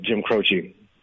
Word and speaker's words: Jim Croce: I just wanted to point out that the first two syllables are Jim Crow of Jim Croce.